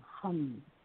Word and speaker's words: ham: Now, inside that ball of light, you're going to imagine this sound, hum. It's spelled H-A-M, hum.